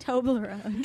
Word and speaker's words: Toblerone: How do you say it again? Turbolone chocolate stuff. – Toblerone. – Toblerone. – Yeah. Toblerone.